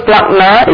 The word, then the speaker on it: splankna: The word splagna is spelled S-P-L-A-N-K-N-A, splagna, the N is silent.